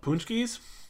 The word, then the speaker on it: paczki: But the other thing I remember from Fat Tuesday and Mardi Gras and stuff are called punchkis. – Okay. – I don't know if you heard of them. – Nope. – Yeah. If you pull up on Google, it's spelled PACZKI.